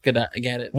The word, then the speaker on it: Gadot: All right, MBK, Mary Bankio, Olivia Munn, Gal Gadot. Or Gadot. How the hell do you say her name? – Gadot.